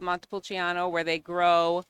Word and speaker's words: Montepulciano: So it's pronounced Montepulciano.